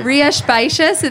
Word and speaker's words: Rías Baixas: They don't know there's an X in there. – Right. – Go ahead. – Yeah. Rías Baixas, it's spelled R-I-A-S-B-A-I-S. B-A-I-X-A-S.